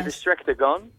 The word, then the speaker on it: Distractor Gun: De Distractor Gun, zeg ik het goed zo? – Je zegt het helemaal goed. Yes. De Distractor Gun.